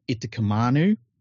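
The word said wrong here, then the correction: Utoikamanu: I think I've got his name wrong there. But Yutikamanu. – Yeah, Yutikamanu. – Very, very good forward.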